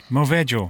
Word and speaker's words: Maggio: Movejo.